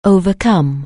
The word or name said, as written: overcome